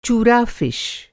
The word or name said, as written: चूरा फिश